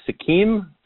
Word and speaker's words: Sikkim: A, a city in India, I believe it's uh, Sikkim. Is that how you pronounce it? That yeah, um, yeah.